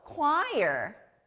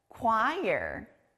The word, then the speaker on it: choir: 이 다음 단어도 K 소리가 나요. 바로 합창단 choir이에요. 앞에 K 소리 들렸나요? 여러 사람들이 모여서 아름다운 노래를 부르는 합창단 영어로 choir. choir.